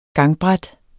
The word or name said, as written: gangbræt